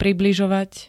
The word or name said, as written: približovať